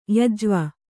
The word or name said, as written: ಯಜ್ವ